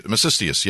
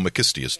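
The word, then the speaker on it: Masistius: Macistius, yes, or Macistius, depending on how you say it.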